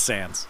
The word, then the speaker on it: Sans: Is it pronounced Sans? – It's Sans. – Sans? Okay. Okay, good. I did it right then.